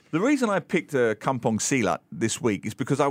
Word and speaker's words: Silat: Silat, yeah. Like the sport.